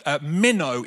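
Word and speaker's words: meno (μένω): It's a Greek word, meno is how it's pronounced.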